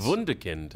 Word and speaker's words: Wunderkind: Is it Wunderkind? – Yes. Wunderkind. – Yeah, that's why I always hear it's Wunderkind.